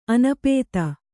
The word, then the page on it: ಅನಾಪ್ತ: anāpta